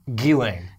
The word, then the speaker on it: Ghislaine: Ghislaine. – Hey, by the way, no idea what that Ubisoft game's called. I can't remember it right now. – It's Hyperspace? – It's called the Ghislaine Shooter.